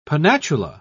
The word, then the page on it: pennatula: British English